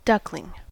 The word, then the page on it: duckling: American English